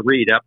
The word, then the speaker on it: Reid: Is that how you say your last name, Jimmy? – Yeah, Reid. R-E-I-D. Yep.